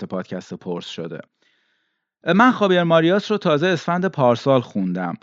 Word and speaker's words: Marías: و فامیلیش هم به صورت ماریاس خونده میشه که حالا من یکم راحتتر و فارسیتر و اینا تلفظش میکنم.